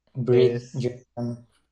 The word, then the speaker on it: Bridgerton: No sé cómo pronunciarlo, que es Bridgerton o algo así. Bridgerton.